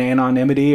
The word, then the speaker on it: anonymity: Did you say anonymity? – Yeah. – Wow, you really sounded that out. – How do you say it? – Anonymity. – Yeah, perfect. That's what I'll give you a little hint. – Yeah. – Because a lot of people struggle with that word.